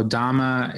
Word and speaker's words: dhamma: So dhamma as in the Pali word, not Sanskrit.